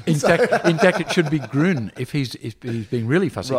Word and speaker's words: Gruen: it's actually Gruen, like one syllable.